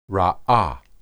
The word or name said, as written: raah